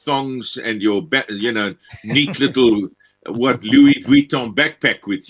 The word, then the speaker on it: Vuitton: It's Louis Vuitton.